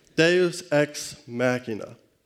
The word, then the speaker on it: Deus ex machina: Now, it looks like Deus ex machina, but I have been informed by someone who loves theater, my wife, that that is not how it's pronounced. Deus ex machina.